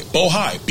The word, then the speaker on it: Bohai: I want to make sure I pronounce it right. Bohai, B-O-H-A-I.